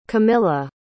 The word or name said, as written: camilla